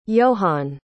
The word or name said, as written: johann